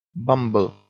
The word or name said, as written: bumble